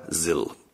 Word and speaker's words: ZIL: Es schreibt sich in Deutsch also Z-I-L, spricht sich aber Sill.